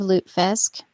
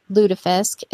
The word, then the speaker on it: lutefisk: And we do not, for anyone that is aware of lutefisk or lutefisk is the Norwegian pronunciation, we do not.